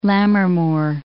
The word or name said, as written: Lammermoor